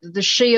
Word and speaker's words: Sidhe: I mean, it's pronounced She, but it's spelled S-I-D-H-E, which is has his Irish origin.